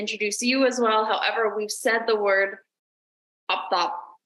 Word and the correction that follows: orthoptist: Oh my gosh, I keep saying it wrong. Orthoptist. I don't know why I cannot say this. Orthoptist. Yep. Yes, I say the R.